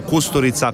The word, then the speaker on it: Kusturica: perciò Kusturica, o Kusturica come si pronuncia in realtà,